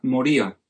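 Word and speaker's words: moría (μωρία): Se pronuncia moría.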